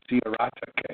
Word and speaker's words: Sciarratta: Tonight, we have California trial lawyer Stephen Lopez, who was the victorious attorney in, I just learned how to pronounce it, the Sciarratta case in California,